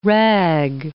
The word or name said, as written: rag